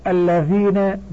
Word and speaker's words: الذين: 1- تكتب الذين بلام واحدة، وتلفظ بلامين لأنها مشددة.